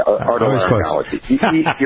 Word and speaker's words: otolaryngology: He's the chief software architect as well as the CEO of WRS Health, practicing head neck surgeon, I never can pronounce this. Otolaryngology. Pronounce it for me, will you? – You got it. Otolaryngology. Your nose is throat.